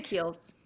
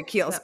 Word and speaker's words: Kiehl's: And then I take my Drunk Elephant drops and my sunscreen of the day, which is either the Glow Screen or the Kiehl's. I don't think I say that right. You know, the K-E-I. – I say Kiehl's. – Okay, Kiehl's. Okay, yeah, yeah.